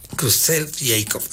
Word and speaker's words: Creutzfeldt-Jakob: Creutzfeldt-Jakob, así se pronuncia.